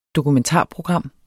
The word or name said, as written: DOKUMENTARPROGRAMMER